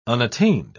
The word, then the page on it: unattained: British English